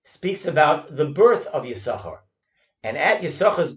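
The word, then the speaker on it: Yissachar: It is for that reason that we do not pronounce the second sin in Yisachar, because that sin was given away to his son Yoiv. Reb Chaim Kanievsky, in his Sefer Taima Dekra, says that the Chazon Ish would tell the Baal Koreh, specifically in Parshas Vayetze, to pronounce Yisachar's name Yisoschar, and in the rest of the Torah to pronounce it Yisachar.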